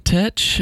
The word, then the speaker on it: Tetch: Me and Steven were trying to figure out how we could let people know it's pronounced Tetch, just visually. – Yeah. – I don't know how to do that. Maybe we could take a book from Kevin, put a line over the CH.